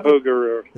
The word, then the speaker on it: Cuyahoga: How do you pronounce that river that was on fire in the Cuyahoga? 70s? The Cuyahoga? Cuyahoga River. Is that it? Yeah, yeah.